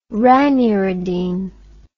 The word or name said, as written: raniridine